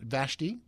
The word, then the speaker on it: Vashti: I think she pronounced Vashti, like the queen in the Book of Esther.